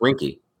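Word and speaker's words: Weinke: It's not it's not like W-I-N. – It is W-E-N. – No, it's spelled W-E-I. – Right. – But it's pronounced Wanky.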